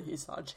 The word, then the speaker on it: Hysaj: Da jeg var på backpack bane, altså alle Hysaj hele tiden. – Mm. Så jeg er helt sikker på at det er sånn det uttales.